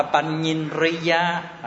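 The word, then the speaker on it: paññindriya: Panyinriya, đọc là Panyinriya, à, là tuệ, đúng là tuệ chủ, à, hay là tuệ căn.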